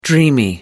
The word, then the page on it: dreamy: American English